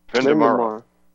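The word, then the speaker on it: Vendémiaire: Vendemiaire. – Okay, I'm not very good at French, obviously. And you begin the article talking about something that took place on the 13th of October, Vendemiaire,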